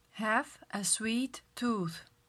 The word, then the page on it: sweet tooth: slow